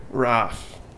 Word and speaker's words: roof: It's spelled R-O-O-F. How do you say it? – Roof. – Right. You know anybody else who says it a different way, even though it's spelt the same way? – Rough. No? – Rough.